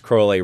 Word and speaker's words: Crowley: reminding you throughout the essay it's pronounced Crowley, rhymes with holy.